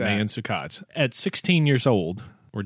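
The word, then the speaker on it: Maewyn Succat: He was actually named Mayan Suket, not Maywan Suket, which some people pronounce it. – Are you serious? – I mean, it's spelled with M-A-E-W-Y-N, and then the last name is S-U-C-C-A-T.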